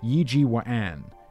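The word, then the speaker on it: Yi Ji Wa'an (葉繼歡): In Mandarin, it'd be pronounced as Yi Ji Wan, Wa'an, Yi Ji Wa'an, instead of Yip Kai Foon.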